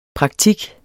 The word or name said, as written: praktik